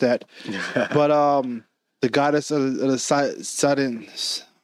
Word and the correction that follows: Sidonians: I should have practiced this beforehand. you read Sidonians. – There. Sidonians. There you go.